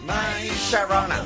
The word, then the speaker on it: My Sharona: ¿Lo he dicho bien? – Mira, ellos te lo dicen. My Sharona. Pues mira, lo habías dicho bien.